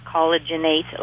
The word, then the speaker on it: Collagenate: Yes, Collagenate is with an A-T-E on the end, not the number 8. It's Collagenate. So it's Collagenate, like rejuvenate. Mm-hmm. And Collagenate is the name of the product.